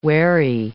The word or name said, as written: wherry